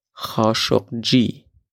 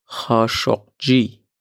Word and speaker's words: Khashoggi: Der Mann wird wie folgt ausgesprochen. Khashoggi. Ich wiederhole, ha shok Khashoggi. Und nochmal ganz langsam, ha shok Khashoggi. Ich wiederhole, ha shok Khashoggi.